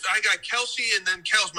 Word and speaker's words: Kelce: He said on a show today that it's pronounced Kelce, not Kelce.